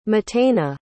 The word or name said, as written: matana